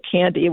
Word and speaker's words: KNDY: It sounds like candy, whereas Halloween, but it's actually K-N-D-Y, and it's just pronounced candy.